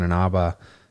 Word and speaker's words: Inaba: Well, apparently the drama is that on Dancing with the Stars line, Carrie Ann Inaba got is that how you say her name? – Yeah.